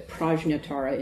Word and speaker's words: Prajnatara: His teacher, Hanyatara, or Prajnatara in the original pronunciation, may have been a woman.